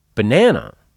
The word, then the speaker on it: banana: Banana has three syllables, and the first and third ones are schwas. But here's the really interesting thing. The vowel in all three syllables is the letter A. But in the first syllable, it sounds like uh. In the second syllable, it sounds like ah. And in the third, it's again.